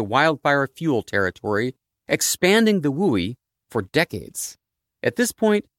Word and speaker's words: WUI: That's the goofily named Wildland Urban Interface, which is pronounced, even more goofily, WUI.